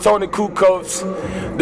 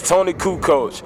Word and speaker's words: Toni Kucoach: Okay, well, first and foremost, Toni Kucoach, depending on how you spell it, is what you're gonna pull up, nigga. T O N I space K U C O A C H. Toni Kucoach.